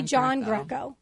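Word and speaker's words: Giangreco: I'm going to do right by my Italian heritage, but I'm going to say my name properly. – Which is? – So I John Greco, Emily John Greco.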